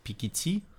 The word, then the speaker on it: Пикетти: Ну, а в этом контексте, нет, ну, в этом контексте, допустим, вот если ты заговорил про примеры революции, вот есть Пикетти, да, наверное, правильное вот так ударение.